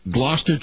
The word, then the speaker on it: Gloucester: Yep, Gloucester.